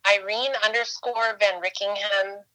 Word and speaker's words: Irene VanRyckeghem: I'm just Irene_VanRickingham on Instagram.